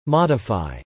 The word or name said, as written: modify